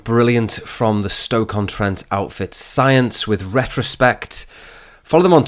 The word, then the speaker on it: Psyence: So they're called Psyence. It's spelled P-S-Y-E-N-C-E.